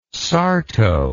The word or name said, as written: sarto